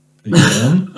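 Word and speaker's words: Jån: But spelled like Jan. – Yawn. – Sure, yawn. – It's J and then the A with a little circle over yeah.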